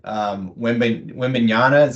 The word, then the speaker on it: Wembanyama: And for those Jazz fans who might genuinely be wringing their hands about what this all means for snagging Wembanyama, is that how you say his name?